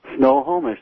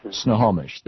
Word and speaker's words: Snohomish: I think I just mispronounced your town, though. – Snohomish, that's how it is. – Snohomish, there you go.